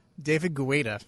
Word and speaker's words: Guetta: David Guetta, I believe is how it's pronounced.